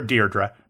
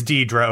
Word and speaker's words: Deirdre: They called her Deirdre. The first R is silent. It's Deirdre O'Connell.